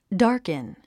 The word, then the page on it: darken: dάː r kən; ダーケン